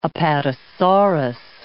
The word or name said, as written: apatosaurus